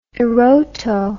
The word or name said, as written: eroto